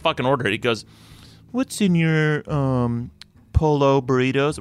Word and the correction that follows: pollo: We're like, Roy, it's pollo.